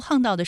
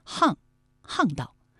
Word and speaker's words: 巷道: 那其实在读巷道的时候，可能有些朋友就会误读为巷道，那其实是不对的，我们要记住它还有另外一个读音呢，就是巷，巷道。